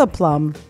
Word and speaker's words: aplomb: Vince slides right into the director's chair with a plum. – And that is spelled A P L O M B. That is correct.